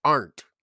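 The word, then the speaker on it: Arndt: Wyatt got back to me about his last name and it's pronounced Arnt. – Arnt. All right, there we go. – The D is silent.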